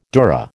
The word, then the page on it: dura: American English